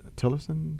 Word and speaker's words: Tillerson: Interesting that we're talking about this topic, and this past weekend, Secretary Tillerson, did I say his name right? – Sounds right, yes.